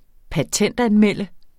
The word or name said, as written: patentanmelde